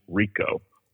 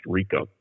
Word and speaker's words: Ricau: And this collector, his name was Jimmy Rico, R-I-C-A-U, is a French name, pronounced Rico.